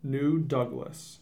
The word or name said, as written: New Douglas